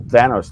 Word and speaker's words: Thanos: I hated Endgame because I hated the whole Thanos thing because it was clearly obvious that they were trying to make Thanos, which rhymes with Davos, into a sympathetic character about how he needs to kill off half the universe because he's just the ultimate Malthusian jackass.